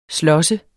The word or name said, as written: slåsse